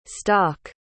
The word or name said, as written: stok